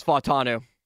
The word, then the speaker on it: Fa'atanu: So we're saying Fa'atanu. Say that again with the extra emphasis on the AU. – Fa'atanu. – Fa'atanu. Are we saying Fa'atanu, or are we just saying Fa'atanu? That's the question. – That's the question. – The best part about it is I've been around a lot of football coaches. – Yeah, it is Fa'atanu.